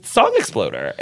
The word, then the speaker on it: Song Exploder: the other podcast that you host, it's amazing, is Song Exploder.